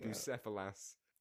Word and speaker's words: Bucephalus: Bucephalus.